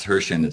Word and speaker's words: Herschend: Hershend, yeah, it's Hershend. It's not the Hershey. It's Hershend. It's just yes. The name of the family that owns. – Darn it. Hershend.